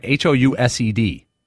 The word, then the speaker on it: housed: What is it's spelled the same way, housed. – Housed. – But you gotta pronounce the S. Not like a Z.